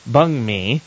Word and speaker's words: banh mi: Winter, our local foodie column and all things recipes, world connoisseur, has a new recipe piece up this week on the banh mi, pronounced bung mi, so you'll want to check that out.